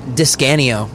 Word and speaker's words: Discanio: How do you say your last name? – Discanio. – Discanio? – Discanio. Yeah, it's Italian.